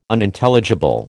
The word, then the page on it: Unintelligible: American English